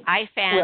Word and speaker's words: Ifans: I have no idea if I'm pronouncing his name correctly. I have really bad luck pronouncing Welsh names. So I know Rhys is right. But Ifans, right. – I believe that's how you pronounce his last name.